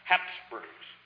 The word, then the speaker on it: Habsburg: H-A-B-S-B-U-R-G-S. The Habsburgs. In German, a letter B after a vowel is pronounced like a P. So this is pronounced Habsburgs, but properly spelled H-A-B-S-B-U-R-G.